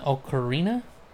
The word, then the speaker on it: ocarina: Um It's an ocarina? Ocarina is how you say it.